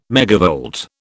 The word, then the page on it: megavolt: British English